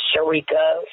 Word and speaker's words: Sherika: So you have on Instagram, my name is Sharika, and you spell that S-H-E-R-I-K-A.